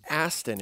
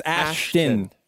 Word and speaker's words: Ashton: It's not Aston. – It's Ashton. Yeah, I was soliciting.